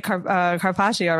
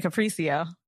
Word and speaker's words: carpaccio: I didn't used to say carpaccio, right? I used to say capriccio.